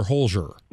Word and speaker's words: Holzer: For those who don't know, The Holzer Files, and am I pronouncing Holzer correct or Holzer? – Holzer, yep.